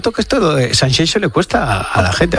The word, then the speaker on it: Sanxenxo: y te cuento que este fin de semana en Sanxenxo, no Sanxenxo, como dicen. – Qué bien lo pronuncias. – Sanxenxo, ¿eh? – Es como Shakira.